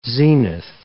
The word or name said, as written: zenith